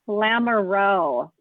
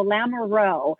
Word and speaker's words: Lamoureux: Lamoureux. That it's just hard to come. It's hard to come out of my mouth there. So Lamoureux.